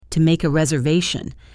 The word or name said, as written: to make a reservation